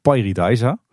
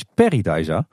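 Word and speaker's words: Pairi Daiza: Ja, nou de, meeste mensen in Nederland zeggen Pairidaisa. En ik kwam toen heel triomfantelijk terug uit dat park met jongens, zo spreek je daar helemaal niet uit. Het is Paradise. En toen werd ik door de ganse Nederlandse pretpark podcast wereld belachelijk gemaakt. Want dat was toch echt Paradise.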